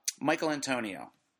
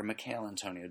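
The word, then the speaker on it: Michail Antonio: Michael Antonio, or Mikhail Antonio, depending on how you pronounce it, of West Ham, $7.5 million.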